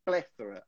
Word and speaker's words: plethora: E aí você fala plethora, não é plethora? é plethora. A ênfase é na primeira sílaba aí.